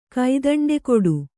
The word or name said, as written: ಕೈದಂಡೆಕೊಡು